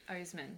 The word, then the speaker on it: Oseman: Oseman, yeah. I believe that's how you say it. – I think that's how you say it, yeah.